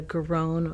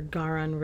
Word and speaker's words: Garonne: We also have the Garonne or Garonne River. I've heard it pronounced both ways.